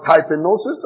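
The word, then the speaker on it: tapeinosis: Is the word typenosis? I'll spell it for you. T-A-P-E. I-N-O-S-I-S. T-A-P-E-I-N-O-S-I-S.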